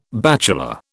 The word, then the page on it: bachelor: British English